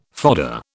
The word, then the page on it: fodder: British English